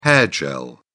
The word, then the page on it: hair gel: British English